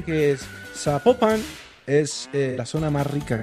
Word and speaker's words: Zapopan: Oiga, nos escriben los oyentes y que no se pronuncia Zapopan, sino que se pronuncia Zapopan. – Ah, Zapopan.